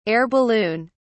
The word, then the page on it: air balloon: British English